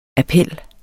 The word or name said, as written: appel